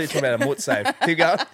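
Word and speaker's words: mortsafe: So have you ever heard of a mutsafe? Sorry. – A mutsafe? Is that how you pronounce it? What a mort. Can you just spell it? – A mort safe.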